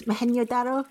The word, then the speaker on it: Mohenjo-Daro: Mohenjo-Daro in the Indus Valley.